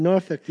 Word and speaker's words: Norfolk: Is that how you pronounce it? – Norfolk, yeah. – Norfolk. – Actually, if you're really southern, you say Norfolk. – Norfolk. – Norfolk. – Yeah, N-O-R. – Fuck. – F-O-L-K. Norfolk.